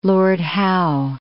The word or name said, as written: Lord Howe Island